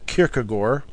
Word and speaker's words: Kierkegaard: And I bet some of you think I'm mispronouncing it, but the correct pronunciation, I am told by people who are of Dutch ancestry and descent, is Kierkegaard.